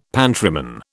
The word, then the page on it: pantryman: British English